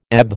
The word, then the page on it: ebb: American English